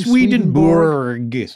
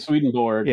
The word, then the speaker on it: Swedenborg: Swedenborg. – Swedenborg.